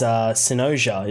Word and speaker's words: Synosia: So the title of your upcoming album is Synosia. Is that correct? Am I saying that right? – I think you are. I've heard it said a couple of different ways. That's kind of the way we pronounce it.